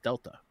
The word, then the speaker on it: Delta: The triangle is pronounced Delta.